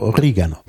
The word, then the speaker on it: Oregano: Das ist nicht die Standardform. Die Standardform wäre Oregano. Ja, auf jeden Fall auf, dem, auf der zweiten Silbe betont.